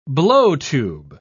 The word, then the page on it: blowtube: British English